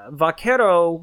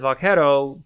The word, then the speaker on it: vaquero: But really, vaquero, you know, it's pronounced as vaquero, eventually got corrupted and anglicized as buckaroo.